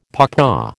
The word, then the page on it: papaw: American English